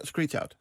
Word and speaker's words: Screech Out: Een stukje uit nummer Screech Out, zeg ik het goed? – Ja. – Ja, Screech Out.